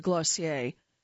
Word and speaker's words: Glossier: That is what has catapulted Glossier, pronounced like dossier, into the pantheon of unicorns, the tech-speak term for startups worth a cool 10 figures.